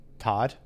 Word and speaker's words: Toht: His name is Major Arnold Todd but it's spelled T O H T,